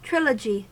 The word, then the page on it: trilogy: American English